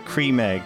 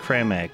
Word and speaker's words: Creme Egg: I just saw the Cadbury's Twitter had replied that cream egg is actually pronounced creme egg.